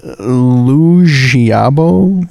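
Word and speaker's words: Liu Xiaobo: Lu Xiaobo? – Yeah.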